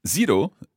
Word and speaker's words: zero: Zero in Italian is very straightforward. It's zero. – Zero. – Zero. – Zero. – It's like a DZ sound at the beginning.